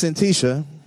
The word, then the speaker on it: Syntyche: And I was just feeling a little extra ethnic today, so it's Syntyche.